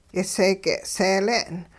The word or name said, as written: yiseege' seele'n